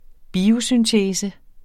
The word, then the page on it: biosyntese: [ ˈbiːo- ]